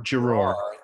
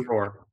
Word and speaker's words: Giroir: Giroir. – Thank you. – Giroir, yeah.